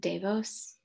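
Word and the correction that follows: DeVos: The Voss family.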